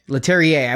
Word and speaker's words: Leterrier: One person's name that I did recognize this time, and how do you say it? Louis Leterrier? – Leterrier. I went to YouTube Leterrier. To validate that one.